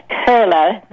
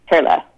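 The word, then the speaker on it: Koehle: It's Kurla, but you have to be a German to know that. My husband is from Germany. In Germany, it would have an umlaut over the O, and that makes the O sound like a U-R. So it's K-O-E-H-L-E, okay. – And it's pronounced Kurla.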